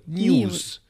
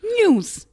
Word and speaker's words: NIUS: Das heißt N-I-U-S, also News. – News. Und genau, News.